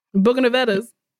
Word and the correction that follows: Bougainvillea: Mm. – Kimmy, do you know how to say it? Bougainvillea? Yep. Bougainvillea.